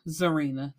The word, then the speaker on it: Zarinah: So let's start with Zarina. That's how I'm pronouncing it. It's spelled Zarinah.